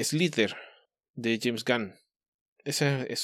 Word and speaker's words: Slither: Slither. Se escribe S-L-I-T-H-E-R.